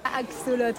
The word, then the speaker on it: axolotl: Axolot, je prononce bien Axolot, ouais.